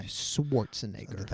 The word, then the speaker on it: Schwarzenegger: Schwarzenegger.